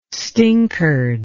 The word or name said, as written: stinkard